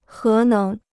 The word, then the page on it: 核能: hé néng